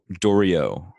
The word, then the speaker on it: Doriot: Let's get to Doriot, it's spelled D-O-R-I-O-T, but rhymes with Oreo, correct? – Rhymes with Oreo.